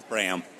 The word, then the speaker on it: Braem: Braem.